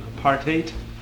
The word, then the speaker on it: apartheid: Okay, the word itself, by the way, note the pronunciation, apartheid. It rhymes with hate, which is very appropriate.